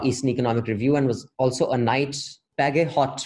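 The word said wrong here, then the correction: Bagehot: Fellow. Is that right? – They say Badgett.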